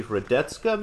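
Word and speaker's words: Hradecká: And Pliskova the Greater beat Lucy Radetzka, maybe is how you say that? What do you think, Jorge? – Yep, you got it.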